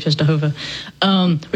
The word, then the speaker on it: Częstochowa: but to Our Lady of Częstochowa, which is my bad pronunciation of Polish. – Close enough, again.